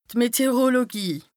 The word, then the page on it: d' Meteorologie: Bas-Rhin; Strasbourg